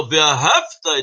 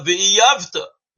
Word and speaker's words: Ve'ahavta: Então, em vez de falar Ve'ahavta et Hashem Elokeinu, você tem que amar o Eterno, teu Deus. Ele fala Ve'yavta, não pronuncia bem, significa você tem que fazer Deus teu inimigo.